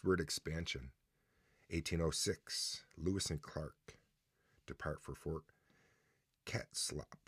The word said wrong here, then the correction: Clatsop: Am I saying that right? You're not even paying. Not even paying attention. Clatsop.